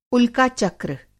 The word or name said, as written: उल्काचक्र